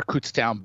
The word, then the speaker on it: Kutztown: Yeah, I mean, I'm I'm uh, inclined to be bullish about the former Kutztown Bear.